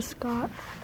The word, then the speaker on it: Scott: My name's Trisha Scott. – Trisha. – Scott. – Spell it. – S-C-O-T-T.